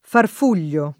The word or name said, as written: farfugliare